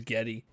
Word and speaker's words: Geddy: His real name is Gary Lee, but his like great-grandmother or something pronounced Gary as Geddy.